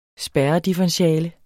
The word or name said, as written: spærredifferentiale